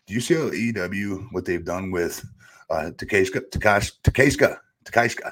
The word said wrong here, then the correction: Takeshita: Am I butchering that? Takeshita. I've got Jim Cornette in my head saying a different name right now, what he says on his podcast.